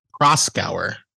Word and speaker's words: Proskauer: So before this story, there was a story where there was Proskauer? How do you say it? – Proskauer. Yes. – Okay.